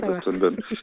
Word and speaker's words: Boston Bun: C'est, alors, tu vas me dire si je le prononce bien, mais c'est Boston Bun. – Oui, tu le prononces bien, ouais, Boston Bun.